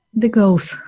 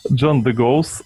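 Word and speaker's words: De Goes: Вот, например, Джон Дегус. Как, как правильно его фамилия произносится? – Дегоус. – Джон Дегоус.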